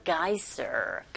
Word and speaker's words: geyser: 빈칸에 들어갈 말이 a geyser. Geyser. 스 발음을 z로 하셔야 돼요.